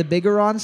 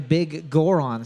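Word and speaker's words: Biggoron: The Bigoron sword? – Yeah, yeah. – I said it's called the Bigoron sword, and she said, no, it's pronounced the Big Goron sword.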